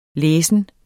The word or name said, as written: læsen